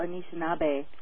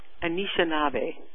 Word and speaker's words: Anishinaabe: It's Little Shell Anishinaabe. – Anishinaabe.